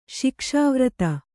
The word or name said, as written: ಶಿಕ್ಷಾವ್ರತ